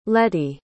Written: letty

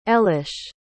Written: elesh

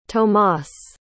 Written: tomas

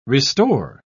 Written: restore

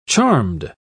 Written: charmed

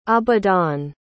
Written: Abadan

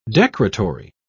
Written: decretory

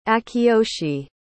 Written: akiyoshi

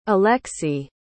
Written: alexi

